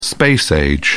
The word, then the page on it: space age: British English